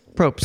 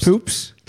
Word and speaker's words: Probst: What's his last name? – Probst. – Poops. – Probst. – Poops. – Probst. Poops. Say it right.